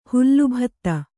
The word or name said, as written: ಹಾಲುಬತ್ತ